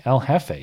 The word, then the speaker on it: El Jefe: El Jefe. You got to pronounce it in the Spanish way.